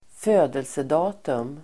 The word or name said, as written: födelsedatum